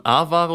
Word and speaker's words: A-Ware: Es ist wirklich kurz zwischendurch, wie spricht man es aus? A-Ware oder Aware oder beides? – Also die Studierenden, mit denen ich zu tun hatte, haben immer A-Ware gesagt. Ja.